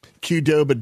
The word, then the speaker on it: Qdoba: Is that how you say it, Qdoba? – Qdoba, dude, Qdoba.